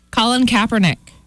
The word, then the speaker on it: Colin Kaepernick: Colin Kaepernick. – Yeah. – He is allegedly, did I say his name wrong? – No, you got it.